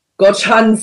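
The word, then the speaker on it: Gocheok: Yes, guys, that's how you say it. Gochan Sky Dome.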